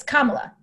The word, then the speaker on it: Kamala: It's Kamala. It's like comma, but la.